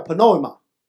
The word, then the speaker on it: Pneuma: 风 Ruach Pneuma 在圣经里面被用来描述灵，希伯来文的发音叫 Ruach，希腊文的发音叫 Pneuma，我们翻译成灵，原来有另外两个含义，是灵也是风又是气。